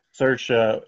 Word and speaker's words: Saoirse: Saoirse,